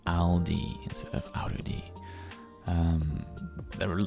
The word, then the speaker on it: Aldi: It's actually a German supermarket, but its name is Aldi. And I remember one of my friends would always pronounce this Arudi.